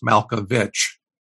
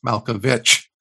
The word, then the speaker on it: Malkovich: And I told him I promised I would write on the chalkboard 100 times, it is pronounced Malkovich. It is pronounced Malkovich.